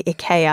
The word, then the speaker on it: IKEA: We have uh, Volodymyr Zelensky on there, but others are more evergreen, uh, like IKEA, or actually, I should probably say IKEA, I think is the, is the correct pronunciation.